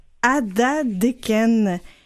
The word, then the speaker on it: Atateken: Et là, l'affaire, c'est que ça s'écrit Atateken et que ça oui. se prononce Adadeken.